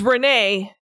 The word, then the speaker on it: Renae: The author's name is Renee, but it's spelt R-E-N-A-E.